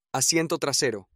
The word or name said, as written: asiento trasero